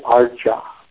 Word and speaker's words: Archa: You're used to saying Acharya, but then you notice there's no second R, so it's, the word is within the Archa. The word Archa, A-R-C, long A, Archa, the deity is named for the deity form, a form of the Lord for worshiping.